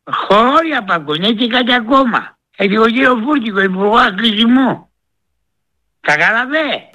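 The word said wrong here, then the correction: Βρούτση: Όχι Βρούτσι, ρε. Βρούτσι.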